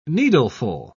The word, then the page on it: needleful: British English